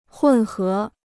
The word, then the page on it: 混合: hùn hé